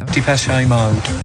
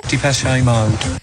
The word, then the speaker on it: Depeche Mode: Depeche Mode. – Ja, so hatten sich Depeche Mode das eigentlich gedacht mit der Aussprache ihrer Band. Achtung nochmal. – Depeche Mode.